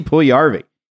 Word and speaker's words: Puljujärvi: They finally got off their asses and made some trades, starting with the Edmonton Oilers and sweet, sweet reliever Jesse Puljujarvi. That's right. It's a soft J.